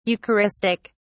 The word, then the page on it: eucharistic: British English